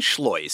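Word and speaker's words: Schleuss: John Schloss, spelled like Seuss, rhymes with rejoice.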